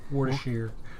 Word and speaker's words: Worcestershire: Like Worcestershire is Worcestershire.